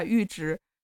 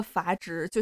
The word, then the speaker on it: 阈值: 里面就会有这个这个阈值，然后我录完这个呃小教程了之后，我就回回看了一下，然后就发现自己把阈值读成了阀值，就像是我刚才把阈值读成了确值一样，